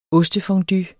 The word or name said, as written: ostefondue